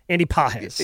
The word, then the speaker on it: Pages: Andy Pages.